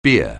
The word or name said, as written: bier